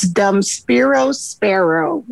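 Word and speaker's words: Dum spiro spero: Yes, it's Dum Spiro Sparrow, which means while I breathe, I hope.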